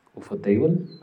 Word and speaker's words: ufotable: Y a raíz de todo este éxito sale el anime que fue lanzado en el 2019 por el estudio ufutable ufutable no sé cómo se le pronunciará. Es inglés. Es inglés, ¿cómo pronunciarías en inglés? Ufotable. Ajá.